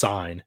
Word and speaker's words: Cine: Pronounced Cine is how you say his last name.